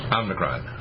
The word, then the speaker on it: Omicron: How do you pronounce it again? – Omicron. – There's no N in there, is it? Just Omicron. – Well, I call it Omnicon anyway.